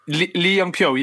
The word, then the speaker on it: Lee Young-pyo: Now, Park moves to PSV, obviously following his former South Korean manager, with Lee Young-pyo, who I have probably just butchered. I've probably just butchered the pronunciation of that. – Pyo. – But Tottenham, Lee Young-pyo, yeah.